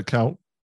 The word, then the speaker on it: Kuyt: Help me pronounce it. Uh, is it played for Liverpool? Kuyt? – Uh, Cout. – Cout. Oh, I'll, I'll, give you that.